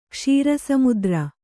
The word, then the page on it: ಕ್ಷೀರಸಮುದ್ರ: Kannada